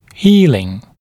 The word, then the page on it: healing: ‘hiːlɪŋ; ‘хи:лин